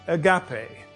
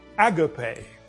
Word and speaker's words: agape: The third word is agape, or agape, depending on which syllable you put the emphasis.